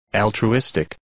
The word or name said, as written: altruistic